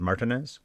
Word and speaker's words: Martinez: Soderbergh on the Traffic commentary pronounced it Cliff Martinez, so Martinez, but he said Martinez, so I'll go with Martinez.